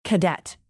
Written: cadet

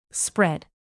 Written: spread

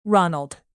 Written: ronald

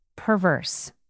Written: perverse

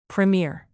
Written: premiere

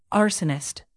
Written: arsonist

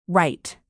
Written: wright

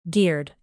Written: geared

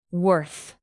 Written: worth

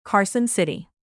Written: carson city

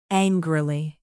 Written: angrily

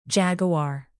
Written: jaguar

